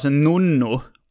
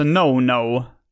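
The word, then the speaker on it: Nono: Jag gissar på att jag ville att det skulle uttalas en nonno. Men det är bara ett N. Ja. Nonno. Alltså no-no.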